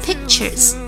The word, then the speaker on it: pictures: 下一个家里挂的画儿 pictures，k 可以不完全爆破，停顿一下 pictures。